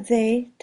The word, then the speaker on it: they: Usa they tanto si estás hablando de objetos o de personas. La TH se pronuncia colocando la lengua contra el filo de los dientes superiores. They.